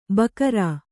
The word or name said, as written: ಬಕರಾ